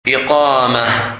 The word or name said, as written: Iqamah